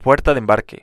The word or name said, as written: puerta de embarque